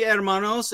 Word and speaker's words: hermanos: He got the silent H. He didn't say hermanos.